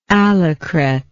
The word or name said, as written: allecret